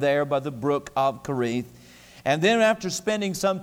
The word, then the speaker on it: Cherith: and we went down to a place called Carith, as many pronounce it Cherith, but we got on the bus.